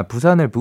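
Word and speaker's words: Busan: 데이먼스 이어의 부센, 10cm의 열대야 그리고 호피폴라의 너의 바다를 추천을 해주셨습니다. 부산을 아 부산을 부산이라고 그 표현한 이유는 제가 그렇게 발음한 이유는 B U S A N이라고 써 있었기 때문입니다, 여러분.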